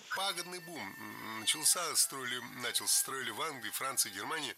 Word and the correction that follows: начался: Много раз прозвучал глагол «начался», но это неверно. Правильный вариант – «начался», только так. Ударение в этом слове падает на возвратную часть «ся». «Начался».